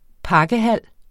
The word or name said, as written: pakkehal